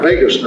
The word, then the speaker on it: vagus: And so a practice of yoga meditation stimulates our vagus nerve that is, is pronounced like Las Vegas, but it's spelled V-A-G-U-S.